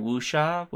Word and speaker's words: wuxia: Yeah, like if I got that pronunciation right, wuxia, with a type yes.